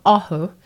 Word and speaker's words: Ohu: It's called Ohu, like awesome. And it's a Te Reo word, and it means people working together.